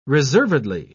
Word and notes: reservedly: British English

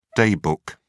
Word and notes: day book: British English

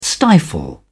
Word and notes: stifle: British English

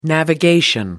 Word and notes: navigation: American English

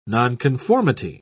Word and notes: nonconformity: British English

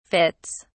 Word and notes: fits: British English

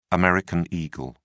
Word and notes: american eagle: British English

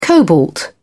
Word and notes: cobalt: British English

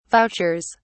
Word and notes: vouchers: British English

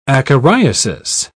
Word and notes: acariasis: British English

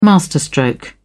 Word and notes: masterstroke: British English

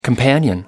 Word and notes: companion: American English

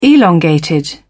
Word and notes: elongated: British English

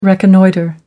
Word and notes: reconnoitre: American English

